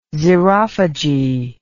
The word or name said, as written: xerophagy